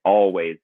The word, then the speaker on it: Alvvays: I recommend everyone listens to the band Up From Your Area called Always, Alvvays, two V's instead of a W, but pronounced Always.